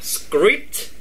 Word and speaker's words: script: Anh gõ vô Zalo, cái chữ này là script. Script. Cái này là 4 5 âm trong một chữ luôn đó, mà em phải đọc là s k r i p t.